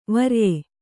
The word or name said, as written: ವ್ರಯ